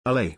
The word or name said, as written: Allay